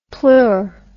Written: pleur

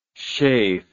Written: shaef

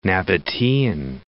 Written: Nabatean

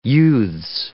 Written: youths

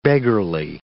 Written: beggarly

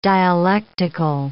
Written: dialectical

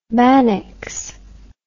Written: mannix

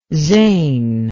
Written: zane

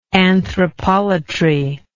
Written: anthropolatric

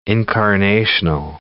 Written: incarnational

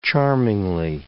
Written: charmingly

